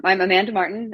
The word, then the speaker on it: Amanda Martin: I'm Amanda Martin.